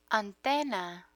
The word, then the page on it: antena: spanish